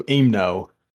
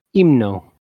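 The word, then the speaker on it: Himno: Yeah, about this it's the H is silent, so Imno. – Imno.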